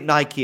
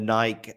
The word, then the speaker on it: Nike: Yeah, so and I believe that Nike is the Greek pronunciation. – Yeah, it does have a bit of a European heritage in that sense. – Yeah, but I think Nike is the correct pronunciation, right? – That's correct.